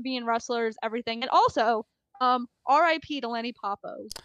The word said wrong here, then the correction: Poffo: Yes, RIP to the how do you say his last name again? Paul Poffo. – I'm illiterate. Paul Poffo.